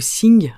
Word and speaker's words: xing: Donc le xing ou sing, hein, mais ça s'écrit avec un x, c'est tout ce qui va s'apparenter à notre esprit.